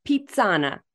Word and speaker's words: Pizzana: In 2017, Candace co-founded her latest venture, Pizzana. Am I pronouncing that right? Pizzana? – Pizzana. – Pizzana. So I got to get that accent there. Pizzana. Thank you. – Embrace your inner Italian.